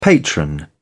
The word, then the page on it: patron: British English